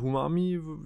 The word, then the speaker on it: Huami: Also es ist eine Untermarke von äh, Xiaomi und zwar Huami, spricht man das so aus? Ja, oder? Huami, ja, genau.